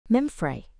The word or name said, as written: Memphré